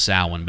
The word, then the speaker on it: Samhain: I know it's pronounced Samhain, but they actually... called the band Samhain, which was Glenn Danzig's post Misfits band.